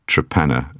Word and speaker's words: Torpenhow: but in fact, Torpenhow, or Trepenna, as it's called, or pronounced, for reasons that aren't immediately obvious, but I think it's just the local dialect.